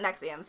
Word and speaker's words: NXIVM: Well, first of all, if you're going to Google it, here's how it's spelled. N-X. I-V-M. – That's confusing. – But it's pronounced Nexium.